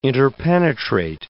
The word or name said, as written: interpenetrate